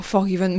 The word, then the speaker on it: Forgiven: Forgiven. On prononce comme ça, Forgiven. – Oh, Forgiven. Mais où sont vos cours d'anglais? – Alors là, j'ai un prof d'anglais.